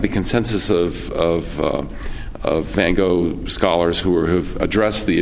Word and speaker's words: Van Gogh: And for those who don't know, and probably some of you do, that Van Gogh is pronounced originally in Holland, Van Gogh. Both of the G's are guttural in G-O-G-H. So it's some people think just one is, but it's Van Gogh.